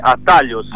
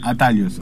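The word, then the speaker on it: Atalhos: El nombre de la banda se dice Atalos. – Atalos, ahí va. – Sí, y en castellano sería como Atajos, ¿no? – Atajos, bien, vamos.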